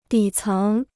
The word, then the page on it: 底层: dǐ céng